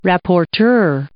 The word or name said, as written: rapporteur